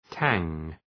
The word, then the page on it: tang: tæŋ